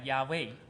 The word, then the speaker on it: Yahweh: According to the Hebrew language, it actually sounds like Yahweh.